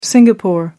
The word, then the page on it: Singapore: American English